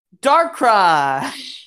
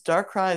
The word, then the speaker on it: Darkrai: Darkrai! – You didn't say it right. – Darkrai!